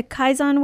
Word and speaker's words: Kaizen: And so what is the, how did you say it, the Kaizen Way? – Yeah, that's exactly right.